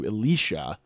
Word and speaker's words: Elisha: Because it sounds exactly like Elijah. So the church actually changed the pronunciation to Elisha so that people wouldn't get confused during Mass.